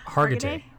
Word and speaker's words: Hargitay: Mariska Hargitay.